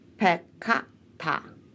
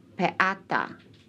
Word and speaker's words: peccata: Peccata is thus pronounced peccata, and not peata. Tuum is pronounced tuum. T-O-L-U-M and not tuum. T-O-O-M.